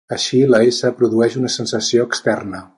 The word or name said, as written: externa